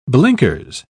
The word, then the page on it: blinkers: British English